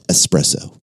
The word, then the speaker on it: espresso: Espresso is not the correct pronunciation of that word. It is espresso.